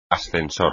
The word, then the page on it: ascensor: espagnol